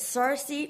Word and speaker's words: Circe: Is it Circe or is it Kyrke? – Great question. So the ancient Greek is Kyrke. It would be a hard C.